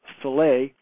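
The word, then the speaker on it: Selye: Dr. Hans, I believe it's pronounced Soleil, explored the connection between emotional stress and illness.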